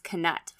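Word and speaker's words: Knut: And a bronze canut, they don't pronounce it nut, it's canut, fun fact, is worth about 1 pence, which is 2 cents.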